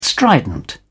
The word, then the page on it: strident: British English